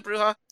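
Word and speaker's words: Brujah: But yeah, the first big set of clans are the Bruja. I don't know which way. Is it Bruja? – Yeah, it's spelt the way you would say it.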